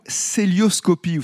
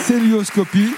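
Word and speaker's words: cœlioscopie: C-O-E ? – Oui. – C-O-E-L-I-O-S-C-O-P-I-E. – Oui, ça s'écrit comme ça, avec le fameux E dans l'eau, il faut le préciser. Célioscopie. – Célioscopie. – Ça se prononce comme ça et ça s'écrit C-O-E avec le E dans l'eau, particularité.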